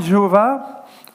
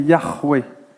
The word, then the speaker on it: Yahweh: Alors, honnêtement, la plupart des linguistes aujourd'hui disent que c'est pas Jéhovah ou Jéhovah, mais Yahvé ou Yahweh. Vous allez, sur le H se prononce R en hébreu. D'accord ? Donc, ça fait Yahweh. Donc, je pense que c'est une prononciation qui est juste.